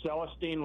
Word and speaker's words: Celestine: Okay, it's Celestine, like Celestine Prophecy.